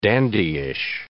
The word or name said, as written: dandyish